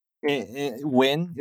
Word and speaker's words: Nguyen: Nguyen is probably a better way to say it. All Vietnamese words are one syllable. So Thompson Nguyen is like pretty good already.